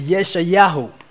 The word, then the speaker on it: Yeshayahu: And the way you pronounce it, is Yeshayahu.